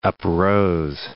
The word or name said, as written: uprose